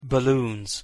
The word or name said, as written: balloons